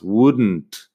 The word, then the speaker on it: wouldn't: Así que eso es bastante sencillo, pero tienes que acordarte de no pronunciar la L nunca en estas palabras. En negación es Igual no tengo ejemplos en la web, pero las formas negativas, wouldn't, couldn't, shouldn't. La L no suena, la N y la T al final sí.